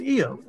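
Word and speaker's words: Iyov: So the Hebrew name is Eov. That's his Hebrew name.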